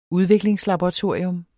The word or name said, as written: udviklingslaboratorium